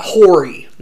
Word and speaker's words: Horry: For those of y'all from not around here, Horry, H-O-R-R-Y. – Not Horry. – Not phonetic. Not at all. I don't know why it's pronounced that way, but it is. We have a lot of places that don't sound the way they're spelled, but it is Horry.